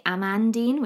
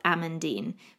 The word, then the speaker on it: Amandine: But with Amandine, most people say Amandine, which is how it's spelt, A-M-A-N-D-I-N-E. So in English, you would say it how it's spelt, Amandine, but it's not, it's Amandine. So you almost replaced the second A with an O if you were looking at it spelt out.